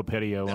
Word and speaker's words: Petiot: Because I listened to some guys talk about on it on YouTube, and they drop the silent T at the end. So Marcel Petiot. – I'm going to go Petiot on that.